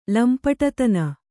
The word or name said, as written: ಲಂಪಟತನ